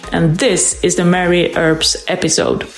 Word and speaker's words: Earps: Earps. It would be great. Earps, though. And if you don't know, then just think of burps, and then it rhymes with that.